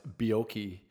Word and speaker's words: Bierko: It's pronounced Bjorki, as in Perfect Strangers.